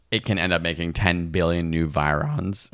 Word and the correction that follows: virions: Virions? How do you say that? – Yeah, virions.